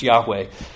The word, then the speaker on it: Yahweh: So the name, the covenant name of God, we think is pronounced Yahweh.